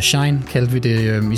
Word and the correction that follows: Shein: Og det, der er en lytter, der er påpeget, det er altså udtales Shien. – She-In. – Ja, fordi det tidligere hed She Inside. – Mm. Og det var, det var for langt at bøvle, så derfor hedder det bare She-In.